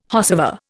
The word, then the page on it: whosever: Indian